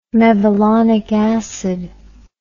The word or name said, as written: mevalonic acid